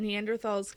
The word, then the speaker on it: Neanderthal: Do you pronounce the, pronounce the th in Neanderthal? Stuff you should know just did this episode, you don't. But ne- they called them Neanderthals the whole time anyway.